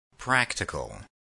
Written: practical

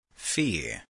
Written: fee

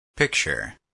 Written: picture

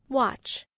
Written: watch

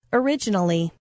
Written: originally

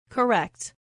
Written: correct

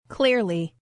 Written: clearly

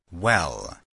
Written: well

